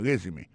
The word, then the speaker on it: resume: I see from your resume, moi, ouais, dans CV ou dans le curriculum vitae, regardez qui j'ai prononcé mon résumé. I see from your resume. Accent tombé sur le premier syllabe là.